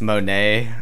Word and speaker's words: Monat: Can you explain to Grandpa what Monat is? – What? – I think it's pronounced Monet. – Monet.